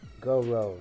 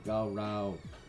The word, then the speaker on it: Gowrow: Gowrow is pronounced right. – Yeah, the Gowrow.